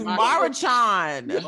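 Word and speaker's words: Maruchan: Murray Chowrien. – It's Marichan. – Five syllables.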